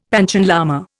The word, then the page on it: Panchen lama: Indian